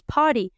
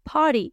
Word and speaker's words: party: Algo que ocurre en cuestión de pronunciación, que se escribe party con T. Lo que pasa es que en los Estados Unidos, en los Estados Unidos, nos comemos la T, ¿no es cierto que sí? es algo típico de los Estados Unidos que diferencia al inglés de los Estados Unidos con el inglés británico de Inglaterra en la pronunciación de las t Entonces nosotros en los Estados Unidos decimos party, party y nos comemos la T, party.